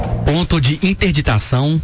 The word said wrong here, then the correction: interdição: De interdição.